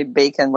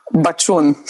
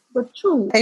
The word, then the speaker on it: Bacun: What is the correct pronunciation? – Bachun. Bachun. You can actually pronounce it pretty well. Usually people can't handle the ch.